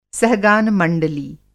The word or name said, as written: सहगान मंडली